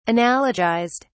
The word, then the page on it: analogized: British English